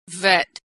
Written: vet

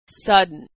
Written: sudden

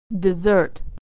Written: to desert

